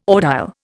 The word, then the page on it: audile: Indian